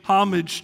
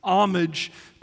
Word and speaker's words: homage: homage to, or I'm sorry, East Texas, homage to. You don't pronounce the, I know, humble Texas.